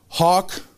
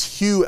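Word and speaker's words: Hough: So if anybody's lived in Vancouver very long, there's a school called, people say it's Hugh Elementary, but it's actually Hawk Elementary.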